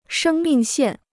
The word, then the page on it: 生命线: shēng mìng xiàn